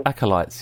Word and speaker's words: acolytes: Is it alkaloid? Is that the word? How do you say the word? You know, like followers or like junior priests or whatever. – Acolytes, yeah.